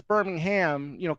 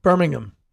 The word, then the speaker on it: Birmingham: But the proper pronunciation, right, is the H is practically silent. – Birmingham, yes. No H.